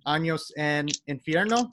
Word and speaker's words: Años en Infierno: It's been a crazy last 30 days, to say the least, since your fourth album, Años en Infierno.